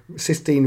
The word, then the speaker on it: Sistine: So Sistine, I forgot to say this earlier, Sistine rhymes with 15.